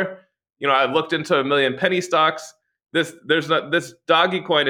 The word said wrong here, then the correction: Dogecoin: it's actually called Dogecoin.